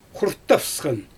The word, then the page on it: xurttafsxan: Xyzyl